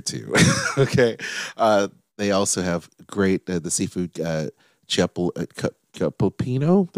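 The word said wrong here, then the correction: cioppino: It's a Chiapino. – There you go. Chiapino rhymes with Filipino.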